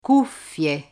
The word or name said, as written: cuffie